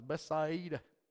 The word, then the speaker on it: Bethsaida: Bethsaida? How you say it? Bethsaida. Okay, that'll work. I'm from the south. It's Bethsaida. We like to say extra enunciations.